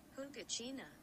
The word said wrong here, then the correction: Huacachina: Oh, she had a very thick accent when she pronounced that. – Huacachina. – Oh, Huacachina.